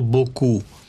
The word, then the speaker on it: beaucoup: В французском есть такое слово «Boku». Пишется «Beaucoup».